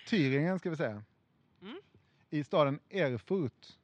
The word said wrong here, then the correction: Erfurt: Mm, bra uttal. – Tack. Eller det var alltså inte bra uttal? – Nej, Erfurt.